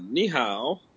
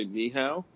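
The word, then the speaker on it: ni hao (你好): And there's this a saying in Mandarin, to say hello, right, is ni hao, right? And you have to say it like that. If you don't say it like that, if you just say ni hao, like I just did, it means kiss me.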